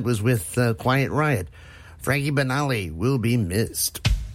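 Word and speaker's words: Banali: Sadly, yesterday we lost Frankie Benali. Benali, I said that wrong. Frankie Benali, who was drummer with Quiet Riot from the original lineup until he passed away yesterday.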